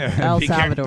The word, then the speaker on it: El Salvador: I don't know how to say the word Al Salvador. – Yeah, it's Al Salvador. – El Salvador.